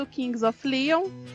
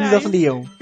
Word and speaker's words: Kings of Leon: Pois é, no ano passado quem ganhou foi o Somebody do Kings of Leon. Falei errado? – Não, tá certo. – Caralho, eu enrolo todo. Eu tô com o Lion na cabeça, caralho. – Kings of Leon.